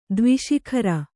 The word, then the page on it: ದ್ವಿಶಿಖರ: Kannada